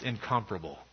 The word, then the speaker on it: incomparable: Seeing all the people who have been liberated from the wrong desires will lead to the understanding that the incomparable, or is that more correctly pronounced incomparable? It seems to me it can go either way.